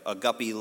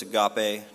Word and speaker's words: agape: You have agape love, you have phileo, and storge. I never heard of storge or storge. I don't know how to pronounce that word. I do know it's agape, not agape, but I like saying agape anyway because it's cooler.